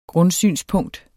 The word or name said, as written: grundsynspunkt